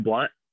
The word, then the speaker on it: Blount: So his prodigy, I like to think about him as as his prodigy is uh, Jeb Blount mm-hmm. Uh, or Jeb Jeb Blunt. It's spelled like Blount.